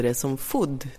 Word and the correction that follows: food: Och då räckte jag upp min beskäftiga lilla hand och sa, fröken, jag tror att det heter food med långt o.